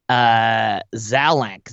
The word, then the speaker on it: Zalank: Zalank, Z-A-L-A-N-K. Just use the hashtag Netheads. – Oh, by the way, I got to tell you something. Zalank? – That's how it's pronounced.